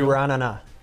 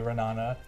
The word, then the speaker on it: Ra'anana: Maccabee Ra'anana.